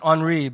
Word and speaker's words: Henri: Henri Nouwen, I think you're supposed to pronounce it Henri, but I'm American, so I'll just call him Henry.